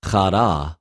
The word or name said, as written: χαρα